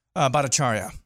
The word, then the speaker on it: Bhattacharya: Bhattacharya.